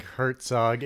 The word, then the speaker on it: Herzog: How are we pronouncing Herzog? – I think Herzog. It's Herzog. Funny to say.